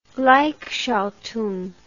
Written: gleichschaltung

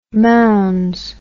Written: mounds

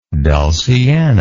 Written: dulciana

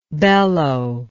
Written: bellower

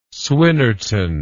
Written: swinnerton

